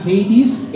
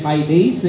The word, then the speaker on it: Hades: वो यूनानी शब्द है जिसका यूनानी में प्रोनाउंसिएशन थोड़ा सा फर्क है, यूनानी में उसका प्रोनाउंसिएशन हाइडेस है लेकिन हिंदी में इंग्लिश में सब लोग हेडीस बोलते हैं।